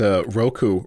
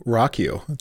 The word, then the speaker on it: Roku: I just like you pronounced Roku, Rockyu. That was great. I never read it that way before. I think that's priceless. – It's R-O-K-U.